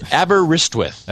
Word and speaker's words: Aberystwyth: And it's Aberystwyth.